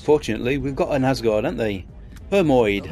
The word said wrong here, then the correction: Hermiod: Hermiod. That's how you pronounce it.